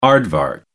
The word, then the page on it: aardvark: American English